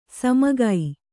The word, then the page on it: ಸುಮುಖಿ: sumukhi